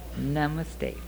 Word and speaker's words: Namaste: Am I pronouncing it right? You've heard it. – Namaste. – Namaste. And this is where they do it. Namaste, friends.